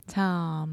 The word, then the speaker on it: Tom: Tom. – Só tem um errinho de pronúncia, que é fechar a boca no final das palavras com um M.